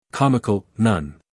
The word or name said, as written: comical